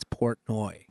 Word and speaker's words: Portnoy: How do you say his last name? – I think it's Portnoy. – Portnoy, yeah. Exactly. Dave Portnoy. What is it? Dave Portnoy? Yeah, that's it.